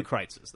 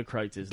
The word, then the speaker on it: Kreuz: Go to Kreitz's, though.